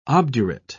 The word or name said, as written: obdurate